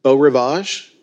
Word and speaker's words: Beau Rivage: And then you have a couple of different casinos, such as, is it Beau Rivage? Is that how it's pronounced? – Yes, okay.